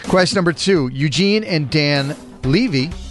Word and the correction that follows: Levy: Levy, that's right. Sorry. I always get it wrong. – It's okay. The wrong emphasis on the wrong syllable.